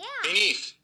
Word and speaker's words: BN3TH: Also, we have Beneath, BN3ETH. Is that how you spell it, Chris? I should look at my underwear. I'm wearing it right now. I'm going to look. Yeah, BN3TH. – Yeah. – Beneath.